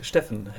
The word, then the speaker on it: Steffen: Zeg ik dat goed, Steffen? Ja, dat zeg je goed, Steffen. En dat komt, die naam komt natuurlijk vanuit het Duits.